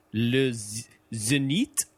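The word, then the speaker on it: Zénith: Oh, is it an accent aigu? Like, so it goes upwards to the right, then it would be zenith.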